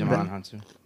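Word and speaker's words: Djimon Hounsou: Dijmon Hansu, is that how you say it? – Mm-hmm. Dijmon Hansu.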